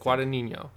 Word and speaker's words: Guadagnino: There's a bunch of other people in it, but it's directed by Luca Guadagnino. Yep. – Guadagnino.